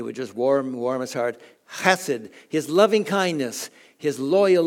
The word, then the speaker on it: chesed: We tend to just pronounce it chesed. It's okay, chesed. But the Hebrews, you got to clear your throat when you say it. It's chesed.